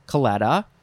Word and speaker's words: claddagh: Last word for this episode is claddagh, claddagh, claddagh, C-L-A-D-D-A-G-H, claddagh. I think that's the best way to pronounce it.